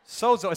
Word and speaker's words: sozo: Okay, sozo, it's actually pronounced sozo, but it's spelled S-O-Z-O.